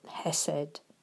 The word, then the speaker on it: chesed: It's spelt C-H-E-S-E-D and we pronounce it hesed.